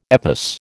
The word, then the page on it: EPOS: American English